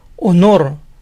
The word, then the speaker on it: honor: La H inicial en la mayoría de palabras en inglés suena a j, pero estas dos palabras son la excepción, se pronuncian con H muda. Hora, hour, hour. Honor, honor, honor. Es como si empezaran con sonido de vocal.